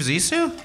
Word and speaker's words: Zissou: The Life Aquatic with Steve Zissou? – Yay!